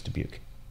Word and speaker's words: Dubuque: Kelly's saying Waterloo in Dubuque. I hope I'm saying Dubuque right. Dubuque. Is that how you say it? I said Davenport. It's Dubuque.